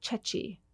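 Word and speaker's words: ceci: and made this pasta e cecchi. Am I saying that correctly? – Yeah.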